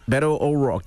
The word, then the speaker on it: Beto O'Rourke: Beto O'Rourke.